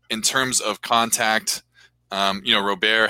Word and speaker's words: Robert: He says Luis Robert, but like it's Louis Robert. Like it's a French, like a French colonized name.